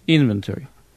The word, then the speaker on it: inventory: То есть inventory. Кстати, inventory – английское слово с двумя ударениями. Inventory, как бы два.